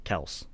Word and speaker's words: Kelce: And like, Mahomes is Mahomes, Kelce is Kels. I think that's how you're supposed to pronounce his name.